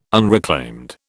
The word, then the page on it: unreclaimed: British English